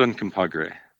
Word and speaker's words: Uncompahgre: I had a near-death experience because I took my bike, and I was biking around beautiful Delta, and I stopped Fort Uncompahgre. Did I pronounce that okay? – Yes, it's Fort Uncompahgre.